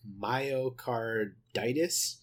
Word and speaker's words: myocarditis: myocarditis, if I'm saying that correctly.